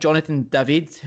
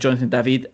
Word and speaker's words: Jonathan David: They have signed Jonathan David, to pronounce it in the English way, but he's French-speaking Canadian, so technically should be pronounced Jonathan David.